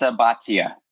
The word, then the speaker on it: Bhatia: Am I pronouncing your last name properly? – It's Bhatia. – Bhatia, yes. I apologize.